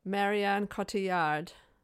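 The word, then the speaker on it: Marion Cotillard: So we get Dr. Lenora Orantes, as you mentioned in your trope, played by I always thought her name was Marion Coulthard. Have I said her name wrong? – Marianne Cotillard. – Cotillard. Thank you. – Yeah. – Maybe. – Marianne. – We'll just Cotillard. – Go with that and apologize for my inability to be able to pronounce this name correctly. – Double L in French is a yeah.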